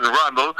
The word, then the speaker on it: Rundle: En cuanto a lo que tiene que ver con eh, los grandes campeones y las juras, van a tener un jurado Adrian Randall. – ¡Epa! Este, ¿Cómo es eso? – Adrian Rundle, se escribe, pero es Adrian Rundle, que es el presidente de la Asociación Hampshire de, Gran Bretaña.